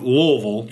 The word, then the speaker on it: Louisville: You know, he was, when I think of Louisville, you got to say it right.